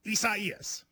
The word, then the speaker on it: Isaias: A lot of people, you know, prayers to everybody involved, but it is Isaias. – Is that how you say it, though? – You do.